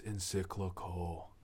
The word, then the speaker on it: encyclical: The Pope has come out with the encyclical. Did I say that right? – Yes.